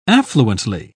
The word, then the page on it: affluently: British English